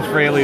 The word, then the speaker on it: Frehley: I know Frehley is the correct pronunciation, sure.